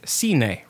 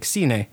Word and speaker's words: cine: So like, you know, I'm like cine, right? Like, you know, going to the movies, you know, like cine. Well, so it's C-I-N-E. So in Spain, they pronounce it cine.